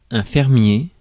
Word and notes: fermier: [fɛʁ.mje]; France

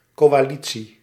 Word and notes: coalitie: /kowaˈli(t)si/

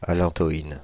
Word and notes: allantoïne: France (Île-de-France); /a.lɑ̃.tɔ.in/

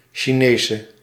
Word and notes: Chinese: /ʃi.ˈneː.sə/

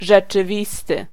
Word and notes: rzeczywisty: [ʐɛt͡ʂɨˈvʲis̪t̪ɨ]

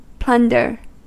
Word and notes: plunder: /ˈplʌndə/; /ˈplʌndɚ/

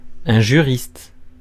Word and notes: juriste: /ʒy.ʁist/

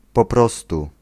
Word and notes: bara: [ˈbɑːˌra]

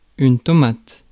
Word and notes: tomate: /tɔ.mat/

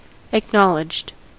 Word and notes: acknowledged: American English; /ækˈnɒlɪdʒd/

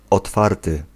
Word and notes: otwarty: [ɔtˈfartɨ]

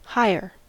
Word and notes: higher: /haɪɹ/; /ˈhaɪə/; /ˈhaɪəɹ/

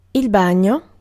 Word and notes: swim: /swɪm/

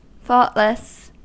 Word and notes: thoughtless: American English; /ˈθɔt.lɪs/

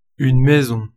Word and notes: maison: /mɛ.zɔ̃/

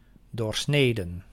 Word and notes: doorsneden: /ˈdoːrˌsneːdə/; /doːrˈsneːdə/

